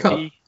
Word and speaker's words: Kup: Coop or Cup? K-U-P? It's Cup. Cup.